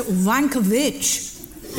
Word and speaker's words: Wankowicz: It's spelled W-A-N-K-O-W-I-C-Z. – Right, so Wankerwitch.